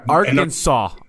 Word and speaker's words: Arkansas: Well, I don't like how you said Arkansas. – Oh, I'm sorry. One. Did I pronounce it wrong? – You best show respect. – No, I'm sorry. Arkansas. I'll be in Arkansas.